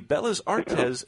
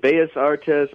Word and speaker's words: Bellas Artes: So definitely a great cigar made by AJ Fernandez, who leads into our next cigar, the Bellas Artes Short Churchill. – Yes. You know, is it Bellas Artes? I'm terrible at pronouncing some of these names, double L is pronounced with a Y.